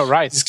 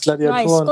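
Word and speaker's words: Ryse: Äh, genau, Rice. – Rice.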